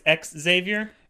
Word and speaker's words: Xavier: He thinks that Xavier, and I'm apparently saying it wrong, it's ex-Xavier.